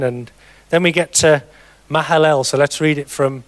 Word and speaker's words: Mahalalel: And after he became the father of Jared, Mahalalel, that's how you pronounce it, lived 830 years and had other sons and daughters.